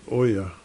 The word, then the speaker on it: Oja: Oja Street, O-J-A. We always pronounce it Oya.